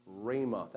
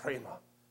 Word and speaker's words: rhema: He uses the word rhema. That's how most people pronounce it. It has one of those rough breather marks. So you could say rhema, but that's really annoying to say that. So I'm just going to say rhema. All right? Is it a deal? I'm going to mispronounce it for your benefit and mine.